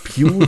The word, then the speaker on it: Pugh: Weil Pugh, das wäre ja eigentlich P-H-E-W, aber naja, scheinbar ist das die richtige Aussprache scheint auch ein sehr charismatischer ähm, Spieleentwickler zu sein, von dem ja nachher dass Dr. Langesco oder Dr. Langeskov.